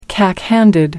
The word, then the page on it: cack handed: American English